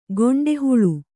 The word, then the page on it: ಗಂಡುಹೊಳೆ: gaṇḍu hoḷe; Kannada